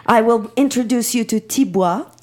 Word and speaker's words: tibwa: And it's called tibois. – Tibois. – That's interesting. – T-I-B-W-A. – Okay. – Tibois. – Okay. Bois, just as it sounds, phonetically.